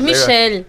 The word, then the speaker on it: Michel: Yo lo pronuncié mal, le agradezco a Gastón Pepe que me, sí, que me dijo, yo dije Michael Jackson. Michael Onfray. Y es Michel, es de Francia. Claro. Es Michel Onfray.